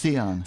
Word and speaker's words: scion: how do we pronounce the word S-C-I-O-N? – Oh, do you want me to give it a try? People say Scion, but it's not. – I think it's Sion.